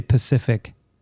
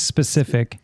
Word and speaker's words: specific: You also say specific wrong. You say Pacific. – Pacific? I do. – It's specific.